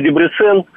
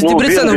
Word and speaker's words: Дебрецен: Вот вы тут сказали, из Будапешта в Дебрецен. – Из Дебрецена в Будапешт. – Ну, неважно, из Дебрецена. В Венгрии всегда ударение только на первый слог.